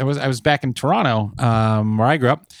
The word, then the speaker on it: Toronto: Toronto. – I know. We don't pronounce the second T. It's a thing. If you do pronounce the second T, you're not actually from there.